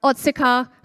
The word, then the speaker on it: Otsuka: We have AEO and we have Otsuka Pharmaceutical. Did I say that right? – Yes.